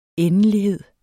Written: endelighed

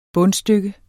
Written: bundstykke

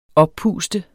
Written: oppustet

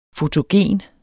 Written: fotogen

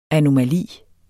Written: anomali

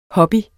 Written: hobbys

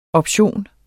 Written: option

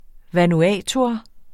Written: vanuatuer

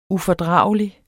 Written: ufordragelig

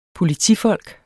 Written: POLITIFOLK

